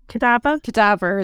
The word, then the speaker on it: cadaver: And the descriptions were flesh rotting away, weak, lifeless, death, of course, wasting bodies, loss of flesh, convulsions, and you would resemble a cavadier. How do you say that? A dead body. – Cadaver. – There we go.